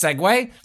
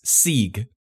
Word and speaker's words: segue: I was like, no, that's not that's segue. – Segue. – Let me start over. I've been mispronouncing for decades, like segue, which for reasons I can't explain, I always read instead as Sieg. S-E-E-G.